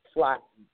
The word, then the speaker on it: Slatten: and I pronounced your name improperly, and so I apologize. It's probably pronounced Slatten. Is that right? – Slatten. Yes, ma'am.